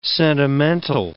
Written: sentimental

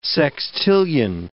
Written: sextillion

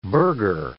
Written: burger

